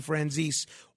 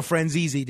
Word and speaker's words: Franzese: Unlike Barry Levinson and Tina Turner, I am absolutely certain that John Sonny Franzese, or Franzese, depending on your pronunciation, is dead.